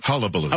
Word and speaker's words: hullabaloo: that all of this hubbubaloo. – Hullabaloo. – Hullabaloo.